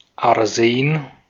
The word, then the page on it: Arsen: /aʁˈzeːn/